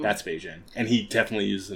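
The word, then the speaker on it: Bijen: It's pronounced like Beijing, but it's B-I-J-E-N.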